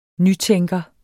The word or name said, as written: nytænker